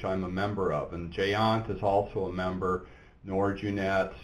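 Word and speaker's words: GÉANT: Is that how you pronounce that? G-E-A-N-T? – GEANT. – Oh, okay. – Yeah. It's Europe, after all.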